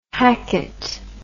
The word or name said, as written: hackette